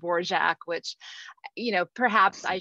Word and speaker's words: Dvorak: But anyway, everybody always called her Dvorak. And that did become the common pronunciation and what she went by. And, you know, that quote, which is, I think it's in a book of quotations about her saying her name was pronounced Dvorak.